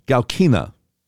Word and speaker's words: Galkina: Mr. Dolan was also in regular communication with Olga Galkinka, another Russian who fed information to Mr. Danchenko for the dossier. What did, I'm sorry, did I mispronounce her name? Olga Galkina.